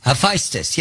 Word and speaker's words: Hephaestus: Hephaestus, is that how you say it? – Hephaestus? – Hephaestus, yes.